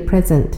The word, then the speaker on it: present: 那么这里的 present 是名词，名词的发音跟形容词是一样的，present。